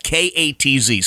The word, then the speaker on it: Katz: Jeff Katz Show. All right. K A T Z.